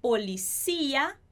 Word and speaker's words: policía: Então, la policía é a polícia. A gente muda aí a sílaba mais forte entre o português e o espanhol.